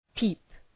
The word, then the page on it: peep: pi:p